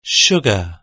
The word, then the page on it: sugar: British English